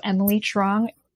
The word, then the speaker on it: Emily Truong: So Emily Truong, which is spelled E M I L Y and the second Y and then T R U O N G.